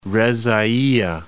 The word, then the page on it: Reza'ayeh: Reza.'a.yeh